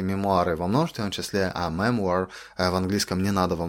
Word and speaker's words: memoir: Based on the memoir.